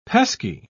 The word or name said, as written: pesky